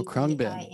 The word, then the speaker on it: Khruangbin: And then, can't never pronounce these guys' name right, but Khruangbin. – Oh, Khruangbin.